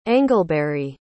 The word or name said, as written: angleberry